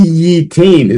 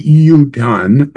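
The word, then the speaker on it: yutan (יֻתַּן): Remember that we have ki yitain is what it's written in the pasuk, ki yitain mayim al zera, and it's pronounced ki yutan mayim al zera.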